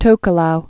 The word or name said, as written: Tokelauan